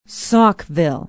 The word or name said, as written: Saukville